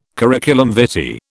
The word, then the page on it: curriculum vitae: British English